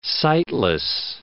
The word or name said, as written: sightless